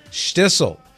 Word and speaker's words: Shtisel: How do you spell Shtissel? It's easy. It's spelled SHT. Careful now, SHTISEL. Got that down, Cyrus? SHTISEL, Shtissel.